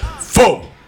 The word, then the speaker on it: pho: It's actually pronounced pho, but a lot of us still say pho. I still say pho, but it's actually pho.